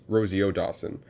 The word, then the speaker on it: Rosario Dawson: A37, who played the mom robot, was voiced by Rosie O'Dawson. – Rosario Dawson.